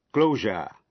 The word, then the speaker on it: closure: Những chữ sau đây, chữ S phát âm là J và tận cùng bởi S-U-R-E. Thí dụ, closure là đóng cửa.